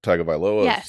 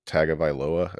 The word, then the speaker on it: Tagovailoa: How do you pronounce his name? – Tagovailoa, I believe.